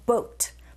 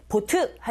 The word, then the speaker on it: boat: 자, 어와 in을 제외한 두 가지 단어 boat. 발음하실 때 보트 하지 마시고 boat, boat. 그리고 comes의 z, 자음의 소리죠.